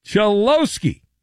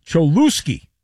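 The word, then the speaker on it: Cholowski: Instead of correctly pronouncing the player's name, Chelowski, which is his name, Manfred pronounced his name Cholusky.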